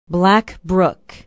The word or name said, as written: black brook